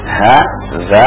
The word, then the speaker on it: hadza: Perhatikan nulisnya ha, za, kemudian alif. Ya. Dibacanya haza. Ha panjang, za. Haza.